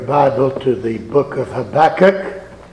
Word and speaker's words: Habakkuk: I'm going to read in a moment from the first chapter of the book called Habakkuk. If you prefer to be correct, you can call it Habakkuk. That's what I'm told is correct pronunciation.